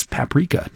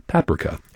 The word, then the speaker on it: Paprika: I don't know why I said it like the British pronunciation. It's Paprika, not Paprika.